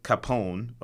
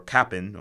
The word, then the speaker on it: Capon: And Tomer Capone, or Capon, or C-A-P-O-N. I don't know how you pronounce it. – It's probably Capon. Capone is with the vowel at the end. Just take it from Italian.